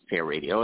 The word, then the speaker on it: hairadio: Again, that's pronounced hair radio. It just has one R in the middle.